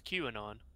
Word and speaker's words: QAnon: Kanon. – Yeah. – Did you say Kanon? I thought it was QAnon. – QAnon or whatever. QAnon, Kanon, like that. Q K. Pronounce, I don't know how much we want to go into this, but yeah.